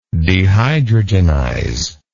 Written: dehydrogenize

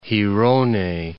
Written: Girona

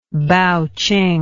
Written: baoqing

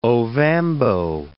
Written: Ovambo

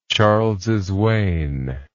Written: Charles's Wain

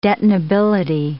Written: detonability

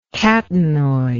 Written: catenoid

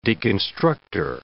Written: deconstructor

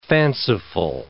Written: fanciful

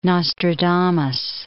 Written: Nostradamus

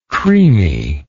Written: creaminess

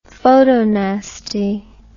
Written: photonasty

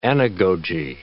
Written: anagogy

